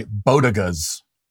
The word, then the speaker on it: bodega: but even I find myself flabbergasted by bodegas. Now, she means, of course, bodega, but she pronounces it like